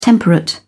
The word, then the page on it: temperate: British English